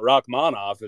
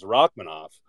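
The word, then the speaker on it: Rakhmonov: It's not, I guess it's not Rachmanov, it's Rachmanov. But no one ever corrects anyone on that.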